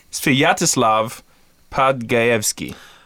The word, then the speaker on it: Svyatoslav Podgaevsky: Svyatoslav Podgivinsky. – That was close. – Podgivinsky, I don't know. – Svyatoslav Podgaevsky. – Otherwise just known as the alphabet. – Svyatoslav. That's how you say it. Svyatoslav Podgaevsky.